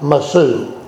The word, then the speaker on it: Mosul: They pronounce it a little different than we do. They call it the city of Mosul. We call it Mosul. – Yeah. – We put the accent on a different syllable.